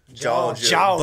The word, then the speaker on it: Jawga: They spell it J-A-W-G-A. Georgia. But it's Georgia Boys.